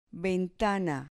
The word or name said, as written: ventana